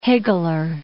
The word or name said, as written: higgler